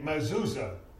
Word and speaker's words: mezuzah: They call it a mezuzah. And I had to look down here. I've got that spelled M-E-Z-U-Z-A-H.